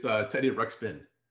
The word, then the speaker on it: Ruxpin: Wait, am I saying the name wrong? – Yeah, it's Teddy Ruxpin. – Oh, Ruxpin. Teddy Ruxpin. My apologies.